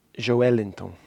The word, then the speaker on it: Joelinton: Joelinton. – Joelinton. – Yeah, the emphasis is on the L.